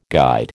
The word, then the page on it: guide: American English